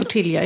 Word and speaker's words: tortilla: Och det är därför tortilla. De uttalar inte ellen, utan det blir tortilla.